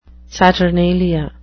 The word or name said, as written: saturnalia